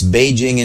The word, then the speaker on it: Beijing: I mean, J is pronounced J in English. It's Beijing in Chinese.